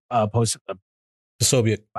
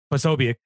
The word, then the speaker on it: Posobiec: Been to the, you know, they went to Chaz and slept in that, like the Jack Posey, Posobiec. – I can never say his name. Posobiec.